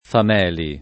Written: Fameli

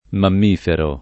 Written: mammifero

